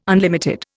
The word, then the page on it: unlimited: Indian